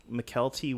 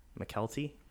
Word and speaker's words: Mykelti: Mikel T. Williamson, I think is his name. – I think it's Michael T. Williams. – No, it's spelled M-Y-K-E-L-T-I Williamson. McKelty?